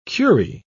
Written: curie

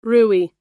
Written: rouille

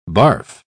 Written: barf

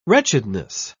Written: wretchedness

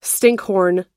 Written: stinkhorn

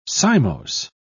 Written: cymose

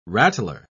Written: rattler

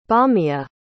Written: Beomia

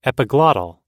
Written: epiglottal

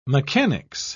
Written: mechanics

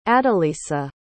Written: adelisa

